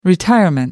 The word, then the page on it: retirement: American English